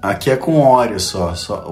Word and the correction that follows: Oreo: Não é óleo, como que é pronúncia em inglês correta? Oreo.